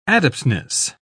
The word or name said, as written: adeptness